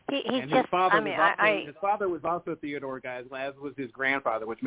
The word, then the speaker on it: Geisel: And the first name, Giselle? What? – Geisel. It's Geisel. – Geisel. Oh, Geisel. I made it French.